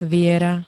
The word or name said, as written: viera